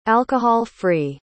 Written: alcohol-free

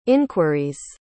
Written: inquiries

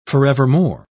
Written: forevermore

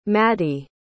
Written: Maddie

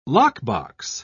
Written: lockbox

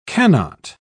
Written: cannot

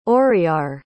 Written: auriar

